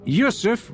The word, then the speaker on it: Yusuf: Yes, it's Yusuf Karim. – Could you spell that for me? – Yes. It's Yusuf with a Y. Y-U-S-U-F. And Karim is K-A-R-I-M. – R-I-M.